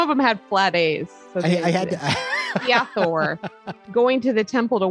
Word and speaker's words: Hathor: I thought it might be Hathor based on some of the other pronunciations of Egyptian deities, but I Googled it. I did research. I Googled pronunciation. It is Hathor.